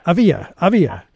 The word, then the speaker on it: Avia: Avaya Kushner, I hope I pronounced that right. Did I pronounce it right? Okay. – It's actually Avia. – Mm-hmm. Avia Kushner is the Forward's language columnist.